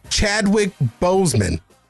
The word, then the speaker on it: Chadwick Boseman: So we lost Chadwick Boseman. Did I say his name properly? – Yes, you did.